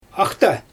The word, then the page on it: axta: Xyzyl